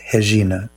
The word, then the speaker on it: Regina: One of the most famous and most important singers in Brazil is the late Elis Regina, pronounced Regina in Portuguese.